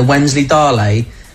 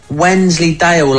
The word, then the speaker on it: Wensleydale: You know the one I was eating, the Wensley Dally? They're saying it's not pronounced that, it's Wensley Dale, like Emmerdale.